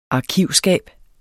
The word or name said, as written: arkivskab